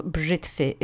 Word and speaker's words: brzytwy: Sometimes it gets softened because in faster speech, the V would be a little too cumbersome to pronounce every single time. So brzytwy is how you would say it, even though it is written brzytwy.